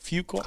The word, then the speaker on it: Fuqua: Directed by Antoine Fuqua. – Yep. Okay. Antoine. How do you pronounce it? Fuqua. – I thought so.